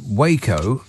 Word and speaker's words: Waco: Now, I know that it's pronounced Waco, but when the Tangerine Scream shows up, it's Waco.